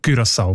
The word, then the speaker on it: Curaçao: Die haben nämlich gerade letztes Dezember die Aussprachempfehlung geändert von Curaçao auf Curaçao. Weil sie beziehen sich dort auf einen Aussprachenduden und auf das digitale Wörterbuch von der deutschen Sprache, wo beide eben Curaçao vorschreiben.